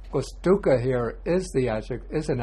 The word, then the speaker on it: dukkha: We say dukkha, long A. Jara, dukkha, long A. Vyadi, dukkha, long A. But maranang dukkhang, here we have the ung sound.